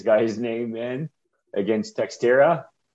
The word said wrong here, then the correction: Teixeira: And Teixeira. – Teixeira. October 30th.